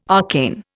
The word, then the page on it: arcane: Indian